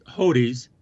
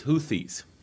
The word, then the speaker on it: Houthis: These were the Houthis. Did I say that right? – I think it's Houthis.